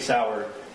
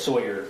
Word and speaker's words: Sauer: So when he mentions the SIG Sauer, he mispronounced Sauer as Sawyer, which if you purchased that firearm and it was for yourself, you'd be familiar with how to say SIG Sauer.